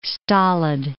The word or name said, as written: stolid